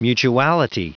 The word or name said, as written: mutuality